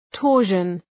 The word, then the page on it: torsion: ‘tɔ:rʃən